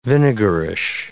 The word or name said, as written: vinegarish